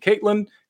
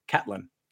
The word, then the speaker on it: Catlin: I was saying Caitlin because that's how it looks phonetically, but it is in fact Catlin.